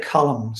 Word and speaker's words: Colm: I would say Colm, C-O-L-M.